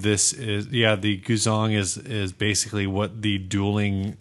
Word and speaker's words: guzheng: I believe it's pronounced Guzong.